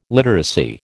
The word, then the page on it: literacy: American English